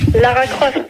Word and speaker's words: Lara Croft: Ouais, ben on va demander à Laura Graf. – Lara Croft.